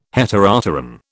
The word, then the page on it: Heteropteran: British English